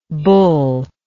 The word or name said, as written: bulllike